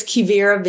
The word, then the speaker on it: Quivira: It's Quivira Vineyards, which is spelled Q-U-I-V-I-R-A, Quivira Vineyards.